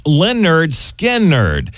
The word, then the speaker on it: Lynyrd Skynyrd: I can actually remember on this date, August the 13th, back in 1973, Lynyrd Skynyrd released their first album, pronounced Lynyrd Skynyrd.